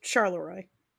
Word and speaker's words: Charleroi: Like the French way, but you pronounce it the way that I said it. – Charleroi? – But one word, no hyphens, just Charleroi.